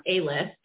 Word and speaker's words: Alyth: So I looked it up, and in Gaelic, um, where it's pronounced A-list, um, it means to rise, to ascend and when in motherhood do you not have to rise to the occasion? – Mm-hmm.